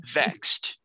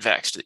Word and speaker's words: VXT: Yeah, they call it Vexed, which I get it and I like it, but if you're gonna do that, just call it Vexed. You don't spell it VXT. Just spell it like a word.